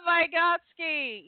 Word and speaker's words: Vygotsky: I think what I read from Vygotsky, is that how you pronounce his name? – I love Vygotsky. Yes.